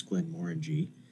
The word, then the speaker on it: Glenmorangie: It is Glenmorangie, like orangey.